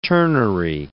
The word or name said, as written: ternary